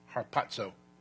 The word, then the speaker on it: Harpazo: Harpazo. H-A-R-P-A-Z-O. Harpazo. And that's a long O, by the way.